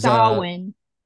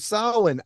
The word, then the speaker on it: Samhain: It's how they pronounce it in like the Wicca. – Uh huh. – Is Samhain. – Oh, yes. Samhain.